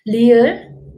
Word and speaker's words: ㄹ: Well, lir actually is neither pronounced as L or R. In English, when you pronounce L, the tongue hit the teeth like L, and R, the tongue is rolled like R.